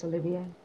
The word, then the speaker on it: Olivier: Olivier? Is that how you pronounce that? – Laurence Olivier.